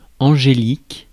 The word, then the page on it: angélique: France; [ɑ̃.ʒe.lik]